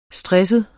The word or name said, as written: stresset